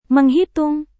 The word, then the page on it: menghitung: Indonesian